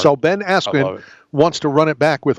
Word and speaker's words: Askren: So, Ben Askren has been all over the Twitter world. – Ben Askew, according to Michael Buffer. – Right. – Got his name wrong.